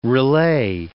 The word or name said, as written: relay